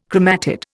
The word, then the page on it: chromatid: Indian